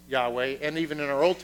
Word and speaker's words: Yahweh: And we pronounce that Yahweh. They actually were not totally sure how to pronounce it,